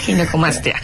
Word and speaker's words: ginecomastia: Y sí, definitivamente creo que es una, para empezar la palabra, le decía, ¿cómo se pronuncia? Ginecomastia, ginecomastia. – Sí, exacto. Es ginecomastia.